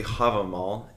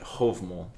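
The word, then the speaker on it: Hávamál: Yes, I, I did, and the one we used previously, Havamal, is is accepted, the more modernized, but the Old Norse is, and I'm gonna butcher this, but Hovmal, and that is how it was said anciently. Spelling's the same.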